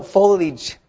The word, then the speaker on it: foliage: So foliage, if you can say it properly, it's fine. But put your tongue between your teeth. Foliage. – Forage. – Lige. The L sound. – Lige. – Yeah, that sounds better. Lige. Bite your tongue, actually.